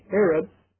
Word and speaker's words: eretz: So every time in Isaiah 24 that I read either earth or land, it comes from the same Hebrew word, which is pronounced in English, eretz, and it means the firm or the land in opposition to the sea.